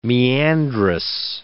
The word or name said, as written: meandrous